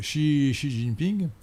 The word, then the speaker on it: Xi Jinping: Et donc ils sont vraiment marxistes, ils sont, ce sont de fervents marxistes, à commencer par le président actuel, euh, Xi, Jinping. Et je ne sais pas bien prononcer le chinois, hein, excusez-moi. comment dit-on Xi Jinping Non je, Oui, oui, vous le dites très bien. Xi Jinping.